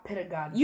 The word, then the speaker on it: pedagogy: Her pedagogy, is that how you pronounce that shit? – Pedagogy.